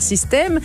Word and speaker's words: Systaime: Mais pas Système comme un système, Système comme Je T'aime. Enfin, ça s'écrit Système, S-Y-S-T-A-I-M-E.